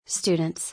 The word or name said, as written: Students